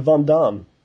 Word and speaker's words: Van Damme: Jean-Claude Van Damme.